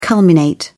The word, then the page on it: culminate: British English